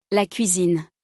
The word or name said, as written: La cuisine